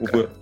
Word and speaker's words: Убыр: Автор таких романов, как «Татарский удар», «Эра Водолея», «Убырь». Э, э, я, наверное, здесь неправильно ударение. Я всегда вот пытаюсь понять, «Убыр» или Убер. Как, как, Убер.